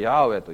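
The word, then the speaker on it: Yahweh: で、ここの YHWH に来るとアドナイとかですね、それからあとですね、シュバーだったかな、なんかとにかく全然関係ない読み方をしたんです。それがためにこの読み方が分からなくなってしまって現在に至っております。まあ、今は我々これ通例でヤーウェと呼んでおりますけれども、でも実際ヤーウェと呼んだかどうかは分かりません。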